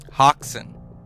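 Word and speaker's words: Häxan: Oxen. Let's make sure that I'm saying it right. Hoxen.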